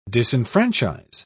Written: disenfranchise

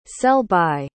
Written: sell-by

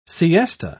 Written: siesta

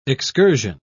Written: excursion

